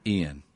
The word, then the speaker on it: Iain: I'm not going to ask you now, but I-A-I-N. – Yes. – And you pronounce it Ian. – Yes, it's the Scottish Gaelic.